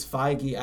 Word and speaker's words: Feige: It's Feige. I actually had to look it up before I came in here and made an ass of myself. It's like pronounced F-Y-G-H-E-E.